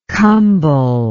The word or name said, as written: kambal